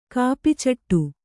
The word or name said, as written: ಕಾಪಿಚಟ್ಟು